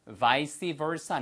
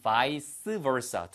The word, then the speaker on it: vice versa: 그래서 vice versa 아니면 vice versa 두 가지 발음 가능합니다.